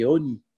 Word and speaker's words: Oni: Mais c'est pas marqué Ani, c'est marqué Oni, avec un O. – Lechem Oni.